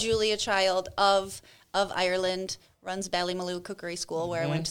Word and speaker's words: Ballymaloe: Yeah, the Ballymaloe. And you said it right. Good job.